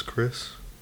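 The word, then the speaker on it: Khris: My name's Chris.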